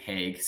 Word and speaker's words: Hague: Um, Nicolas, how do you say that? Hague gets a hooking call against Joe Hague. Pavelski. – Hague, sorry. Hague.